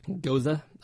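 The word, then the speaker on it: Gose: It's it's tangy like these um, these what do you how do you pronounce goze? – Goza. – Apparently, Goza.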